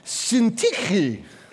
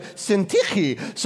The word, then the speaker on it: Syntyche: And I entreat Syntyche. And that's also how you say it. My Greek is terrible, but I'm pretty sure Syntyche.